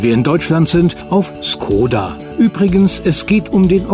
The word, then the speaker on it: Škoda: Demzufolge wird der Firmenname im Mutterland des Fahrzeugs Skoda ausgesprochen. Bei uns in Deutschland sagt man meistens Skoda mit langgezogenem O.